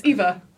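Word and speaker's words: EVE: What about Ava? The bad big robot. – You don't even know. You don't even know her name. It's Eva.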